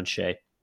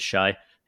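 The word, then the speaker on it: Shai: Yeah, I was most impressed by his defense on Shea. And thank you for pronouncing that right, because I constantly mispronounce it as Shy, if nobody corrects me.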